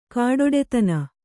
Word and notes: ಖಡ್ಡತನ: khaḍḍatana

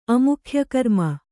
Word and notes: ಅಮುಖ್ಯಕರ್ಮ: amukhyakarma

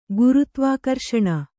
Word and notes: ಗುರುತ್ವಾಕರ್ಷಣ: gurutvākarṣaṇa

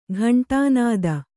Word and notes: ಗಂಡುನದಿ: kannada

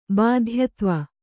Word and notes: ಬಾಧ್ಯತ್ವ: Kannada

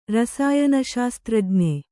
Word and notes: ರಸಾಯನಶಾಸ್ತ್ರಜ್ಞ: rasāyana śastrajña

